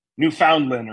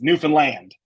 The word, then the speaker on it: Newfoundland: You know, in my good old New England accent, I would probably pronounce it like Newfoundland or Newfoundland or something like that. Mm-hmm. But they're very, very specific that it's Newfoundland. Like, oh, wow. Land is where.